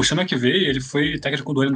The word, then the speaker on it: Sean McVay: O Zach Taylor tem 38 anos, head coach do Cincinnati Bengals, o Sean McVay 36. Falei certo? Sim.